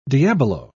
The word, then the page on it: diabolo: British English